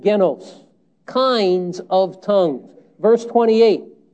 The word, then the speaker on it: genos: The word here, G-E-N-O-S, genos, is how we pronounce the Greek word.